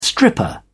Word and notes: stripper: British English

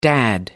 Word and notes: dad: American English

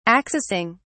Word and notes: accessing: British English

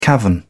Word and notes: cavern: British English

